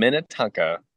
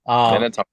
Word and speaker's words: Minnetonka: Did I say that right? – Yes, sir. Redeemer Bible Church in Minnetonka, Minnesota. – Okay. I keep saying that wrong. Minnetonka. – Minnetonka.